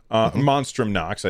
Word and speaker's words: Monstrum Nox: Bloodstained Ritual of the Night and Ease 9 Monstrous Nox. Monstrum Nox. I said that wrong.